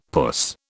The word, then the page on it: puss: British English